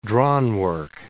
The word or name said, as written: drawnwork